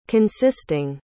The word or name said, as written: consisting